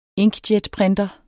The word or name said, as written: inkjetprinter